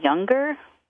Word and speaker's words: Junger: It's by Sebastian, I want to say Younger. It's spelled J-U-N-G-E-R, in case anyone's interested in looking that up.